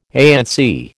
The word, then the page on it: ANC: American English